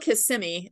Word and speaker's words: Kissimmee: and it's in how do you pronounce the city? K- Kissimmee? It's it's called Kissimmee. It's part okay.